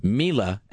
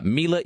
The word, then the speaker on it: Mila: the correct pronunciation. It is Mila, as in like M E E L A, Mila like so, in case anybody if you ever like run into her, uh, Mila Jovovich is how we pronounce her name apparently.